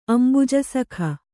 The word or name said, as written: ಅಂಬುಜಸಖ